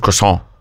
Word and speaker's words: croissant: If you're speaking French, you don't pronounce the T. It's just croissant.